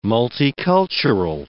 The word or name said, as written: multicultural